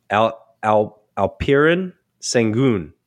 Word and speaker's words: Alperen Sengun: I might say the first name wrong. Alperen Sengun. I butchered that, didn't I, BG? You probably know. – Yeah. No, you did all right.